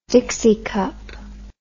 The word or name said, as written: dixie cup